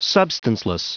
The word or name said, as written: substanceless